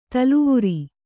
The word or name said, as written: ತಲವಾರ್